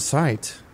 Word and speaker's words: sight: The GH is silent. – Sight, yeah, it is.